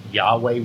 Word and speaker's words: Yahweh: Whenever you see the Lord in all capitals, it is the name Yahweh, we pronounce it, YHWH.